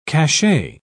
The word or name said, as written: cachet